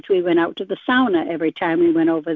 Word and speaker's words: sauna: And one thing that we grew up with was saunas. Americans pronounce them sauna, but it's sauna.